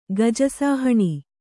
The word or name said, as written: ಗಜಸಾಹಣಿ